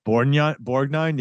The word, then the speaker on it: Borgnine: Ernest Borgnine? – Borgnine, yeah.